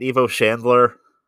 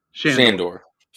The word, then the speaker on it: Shandor: How do you say his name again? Evo Shandler? – Shandor.